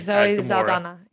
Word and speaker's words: Zoe Saldana: So we got Zoe Saldana. I'm going to say that wrong. I'm sorry. – Zoe Saldana. Yes.